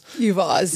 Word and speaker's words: EVaaS: EVaaS, Electric Vehicle as a Service.